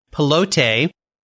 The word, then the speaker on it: Pilote: And I've been saying the title wrong. I know I should have been saying Pilote, Cobra Pilote, because it's spelled P-I-L-O-T-E.